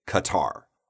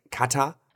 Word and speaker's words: Qatar: Right now, of course, I am not an expert on Arabic, but I know that we as North Americans do not pronounce this accurately at all. However, we do say Qatar. – Qatar，然后英国人，因为你在国外看球，基本上那个 commentator 啊，解说的都是英国的，就呃，因为在英国足球非常受欢迎嘛，然后我就觉得如果听美国人解说，嗯，不不带劲儿，我一定要转成那个 British accent，and they say it differently，对吧？Yeah, it sounds a little bit more like cat, like kata.